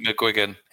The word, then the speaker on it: McGuigan: Is that how you pronounce it? What was it? McGuigan. Yeah.